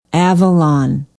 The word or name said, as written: avalon